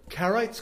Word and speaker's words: Karaites: Karaites, Kraim in Hebrew.